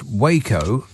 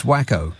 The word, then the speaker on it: Waco: Now, I know that it's pronounced Waco, but when the Tangerine Scream shows up, it's Wacko.